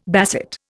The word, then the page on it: basset: Indian